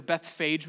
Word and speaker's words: Bethphage: Now that's often how we pronounce it, is Bethphage or Bethphage, maybe you've heard it pronounced. But if I'm not totally butchering this, the Hebrew way to pronounce it would be Bethphage.